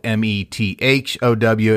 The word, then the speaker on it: Methow: And Methow, by the way, if you're looking at a map, it's spelled M E T H O W. It's not a TH sound, it's a T sound.